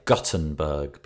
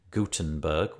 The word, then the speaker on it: Gutenberg: The way it's pronounced is interesting, because I say Gutenberg because I live in the north of England, and G-U-T is gut. – Yes. – Whereas everybody else seems to say goot with a G-O-O-T, Gutenberg.